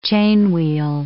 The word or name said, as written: chainwheel